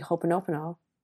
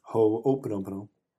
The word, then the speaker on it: ho'oponopono: But focus on one and then do the ho'oponopono. Did I say it right? – Ho'oponopono.